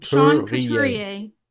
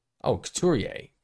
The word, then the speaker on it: Couturier: I don't know how to say his name. Couturier. – Sean Couturier. – Oh, Couturier.